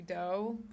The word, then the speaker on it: Do: The song's about a popular East Side realtor in Los Angeles named Tracy Doe, but it's spelled D-O, like Doe.